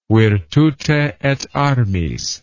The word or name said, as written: virtute et armis